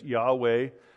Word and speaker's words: Yahweh: There's also YHWH. We pronounce it as Yahweh.